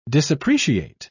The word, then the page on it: disappreciate: British English